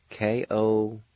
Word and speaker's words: kosdu: Again, the spelling for the word ascertain is A-G-A-D-V-D-I-Y-I. Word number four is ash, and it's spelled K-O-S-D-U. Again, the spelling for the word ash, K-O-S-D-U. Word number five, ashamed. It's spelled A-D-E-H-O-S-G-A.